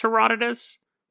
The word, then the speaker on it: Herodotus: I'm sure you guys are probably right that it's pronounced Herodotus. But it's spelled Herodotus.